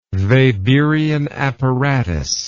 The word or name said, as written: Weberian apparatus